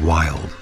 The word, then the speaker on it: YLD: And the set kicked in R&B style with YLD, which you can call them Wild, a new R&B project for pioneering Us 3 producer Geoff Wilkinson.